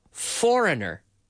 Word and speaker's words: foreigner: Extranjero, foreigner, not a foreigner, but a foreigner, foreigner. The G is silent, foreigner, and not a foreigner.